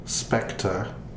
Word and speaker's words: Spectre: Spectre, but it's like it's spelled like pecker. It's better written down. – Speck is in the ham and then ter.